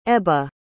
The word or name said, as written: ebba